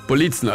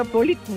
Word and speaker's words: Politzen: Politzen, also die Betonung ist auf dem I. – Ja, Politzen, ganz normal.